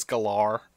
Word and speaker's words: Gellar: That's when I get mad, when I've been pronouncing Sarah Michelle Gellar's name for like 20 years, and then she comes out and goes, actually, it's Galar.